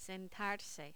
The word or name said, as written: sentarse